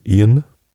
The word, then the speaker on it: Eoin: Oh, von, U oh, wie spricht man das denn aus? E-O-I-N, Ian, Ian Kofer.